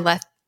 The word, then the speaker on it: lecithin: Using sunflower lecithin, how do you say lecithin? – Lecithin, yeah.